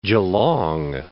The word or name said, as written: Geelong